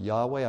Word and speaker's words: Yahweh: Some pronounce it Jehovah, some pronounce it Yahweh. I think Yahweh is closer to the original.